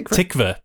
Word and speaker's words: Tykwer: But he Tickver. – Is the Tickver. There we go.